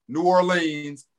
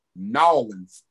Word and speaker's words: New Orleans: You can say New Orleans. New Orleans, and in New Orleans, they actually pronounce it Nolens, N-A-W, Nolens, yeah. Okay. So any of those three work.